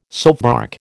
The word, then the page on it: soapbark: American English